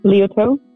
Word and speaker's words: Leotard: Am I saying that right? What's that? – Leotard. – Okay, thank you.